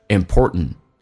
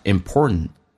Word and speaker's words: important: It is important，而且 important 这个单词在美式发音当中 t 跟 n 组合在一起，最后一个 t 不发音，这叫略音。同时 t 跟 n 组合在一起，t 是可以不用发音，所以你看到很多美国人或者说成 important，气流阻塞一下 important，三个单词就变成 it is important， it is important， to 说成 t，轻轻点一下，it is important to remember that few mistakes are so terrible that they cannot be corrected。